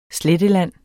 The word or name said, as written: SLETTELAND